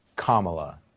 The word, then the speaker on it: Kamala: Names can be so hard to pronounce sometimes. Even the names of people who have been on the scene for years. Say it with me. Kamala Harris.